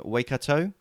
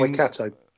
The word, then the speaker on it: Waikato: I'm probably going to make this pronunciation up. Waikato, which is the home. – Waikato. – Okay, thank you.